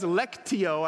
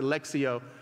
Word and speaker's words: lectio: it says lectio, I know, Divina. Some people pronounce it lexio, but that's, that's where it, it's a Latin word.